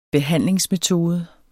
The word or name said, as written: behandlingsmetode